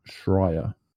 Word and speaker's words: Schreier: I'm looking at the Twitter feed that you got here for us from Jason Schreier.